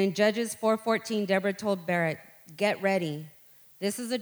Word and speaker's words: Barak: I kept wanting to say Barack or whatever. It's Barak. I kept hearing it.